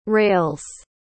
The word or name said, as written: rails